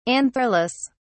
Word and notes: antherless: British English

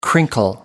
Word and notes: crinkle: American English

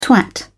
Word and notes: twat: British English

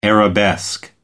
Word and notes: arabesque: American English

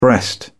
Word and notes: breast: British English

